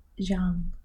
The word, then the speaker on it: Jeanne: However, her name is spelled J-E-A-N-N-E. So it kind of looks a bit like it should be Jean. – Oh, like Jean. – No, it's Jean.